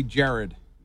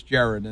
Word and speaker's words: Gerard: Name is actually Jared. It's spelled Gerard, but it's Jared, and my mom will smack you in the head for calling me Gerard, Tom.